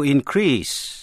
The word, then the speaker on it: increase: Nhận xét cách phát âm, động từ to increase, danh từ an increase, động từ to decrease, danh từ a decrease. Vậy động từ nhấn mạnh vần nhì, danh từ thì nhấn mạnh vần đầu trong trường hợp này.